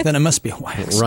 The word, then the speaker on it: Wach: Don't forget, Manny the Movie Guy will join us, and we're going to talk with Dr. Dahlia Wax. How do you think she says it? Any idea, how she says it? – I think it's Wax. – It's Wax, like W-A-X, but it's spelled W-A-C-H.